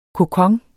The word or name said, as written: kokon